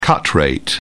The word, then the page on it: cut rate: British English